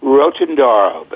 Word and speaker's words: Rotondaro: Rotondaro, Beth.